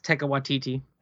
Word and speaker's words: Taika Waititi: Yes, Taika Waititi.